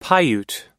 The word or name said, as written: paiute